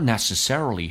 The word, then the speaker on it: necessarily: Some people have more money than others, however, they're not necessarily happier. 然而呢，他们未必会更加的快乐， happier 更加快乐， necessarily 重音在 s 上。Necessarily, 记住那个 A 发 A 这个发音 Necessarily, happier P 浊化成 B 这个发音叫 happier.